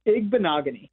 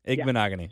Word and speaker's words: Igbinoghene: Igbinoghene. – Igbinoghene.